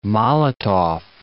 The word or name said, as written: Molotov